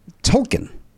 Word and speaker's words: Tolkien: The answer is J.R.R. Tolkien or Tolkien. – Those are pronunciations. – Oh, so he's going alternate pronunciations. – Some people pronounce it Tolkien. – Either answer is okay.